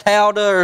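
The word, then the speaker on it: Towdah: Or a Hebrew word for praise, and it's spelled T-O-W-D-A-H. I've always just heard it pronounced Tauda or something like that.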